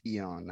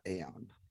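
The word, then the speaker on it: Æon: and it's pronounced Eon, not Aeon.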